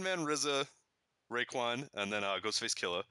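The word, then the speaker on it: Ghostface Killah: Yeah, there's the Ghostface Killa. – Yeah. – You see how I pronounced that? I got with the AH at the end. Killa. There's you meant the RZA.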